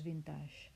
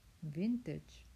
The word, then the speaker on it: vintage: Así que el que le haga ilusión pronunciar bien, que repita conmigo. Vintage, vintage. Es una especie de B, luego T y luego G, como G de James.